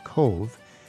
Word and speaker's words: Cobh: It's spelled C-O-B-H, but it's pronounced Cove.